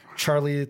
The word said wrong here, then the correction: Charlize: Theron, I always forget how to pronounce her last name. – Charlize Theron.